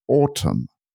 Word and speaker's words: autumn: as autumn with a silent N at the end. Autumn. I don't know if you can hear that the way I say it. A-U-T-U-M-N at the end.